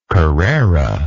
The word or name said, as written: carrera